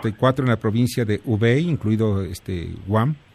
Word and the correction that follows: Wuhan: ¿Cómo se pronuncia? – Wuhan. Wuhan. Wuhan, perdón. Wuhan, de los cuales 671 son graves y 228 críticos.